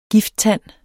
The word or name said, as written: gifttand